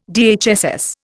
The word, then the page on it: DHSS: Indian